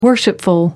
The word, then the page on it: worshipful: American English